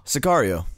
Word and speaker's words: Sicario: Sicario.